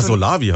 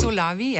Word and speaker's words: Solawi: Ah, Solavi heißt, ich habe es noch falsch ausgesprochen. – Solavi, ja, hm? – ist eine, heißt solidarische Landwirtschaft.